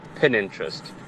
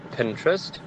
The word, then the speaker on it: Pinterest: For me, it definitely has to be Pinterest when people pronounce it Pininterest. The word reads Pinterest.